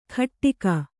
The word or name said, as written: ಘಟ್ಟಿಗ